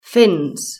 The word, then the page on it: fins: British English